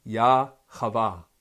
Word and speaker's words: Jehovah: Man and woman in unison are represented by the holy name of divinity in Judaism, Jehovah, properly pronounced Yah-Chavah.